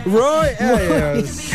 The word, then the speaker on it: Roy Ayers: Roy, Roy Ayers.